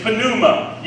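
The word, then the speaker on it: pneuma (πνεῦμα): The word translated breathed, the word that's there in the original language is pneuma. Yes, you pronounce the P. It's not silent in the Greek.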